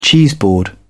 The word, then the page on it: cheeseboard: British English